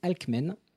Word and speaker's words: Alcmène: C'est pas facile à prononcer, c'est Alcmène.